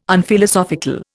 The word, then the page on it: Unphilosophical: Indian